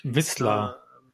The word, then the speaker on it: Wissler: Und das ist dort ähm, die, die Wiesler oder wie die heißt? Wissler. Wissler, ich, ich kann die immer nicht aussprechen. – Also hier steht zumindest W-I-S-S-L-I-R.